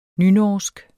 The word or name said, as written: nynorsk